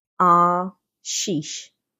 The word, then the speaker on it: ashiysh: Okay, so 808 in the Old Testament dictionary is spelled, I'll spell it A S H I Y S H, but it's pronounced Ah Sheesh. A W S H E E S H, like sheesh and it means pressing down firmly a ruined foundation.